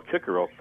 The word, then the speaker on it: Cicero: There's a different pronunciation in Latin where you would pronounce Cicero Kikero.